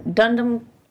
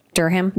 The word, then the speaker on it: Durham: Uh, the Norman Chapel in Dundum. Durham.